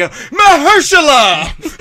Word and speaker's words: Mahershala: And then when the landlord wakes up, I'm like, Mahershala.